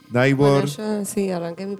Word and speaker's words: Naiborg: Se escribe Naibor, así sí, como con suena. I latina, N-A-I-B-O-R-G.